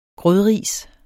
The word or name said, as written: grødris